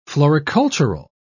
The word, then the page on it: floricultural: British English